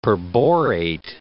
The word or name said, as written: perborate